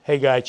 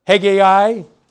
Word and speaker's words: Haggai: Do you say Haggai or Haggai or Haggai? How do you pronounce it? Well, you remember we went through this for Habakkuk too. Is it Habakkuk, Habakkuk, whatever? It really doesn't make that much difference. Haggai is probably closer to the way the Hebrews pronounce it. But I'll be saying Haggai probably. But you can say whatever you want, all right?